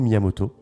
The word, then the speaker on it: Miyamoto: Euh, les créateurs, c'est Shigeru Miyamoto et Takashi Tezuka.